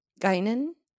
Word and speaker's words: Guinan: And who's Guinan? – Great pronunciation.